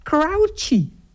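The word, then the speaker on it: Croce: Stond dus, je spreek je uit als... Ik denk, oh, maar dat is mooi. Crouchy.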